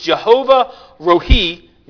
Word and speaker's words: Jehovah Rohi: All right, now, that's how you pronounce it. It's Jehovah Rohi.